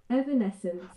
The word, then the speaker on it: Evanescence: Evanescence.